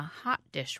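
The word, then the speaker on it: Haute Dish: It was called Hot Dish, spelled like haute couture.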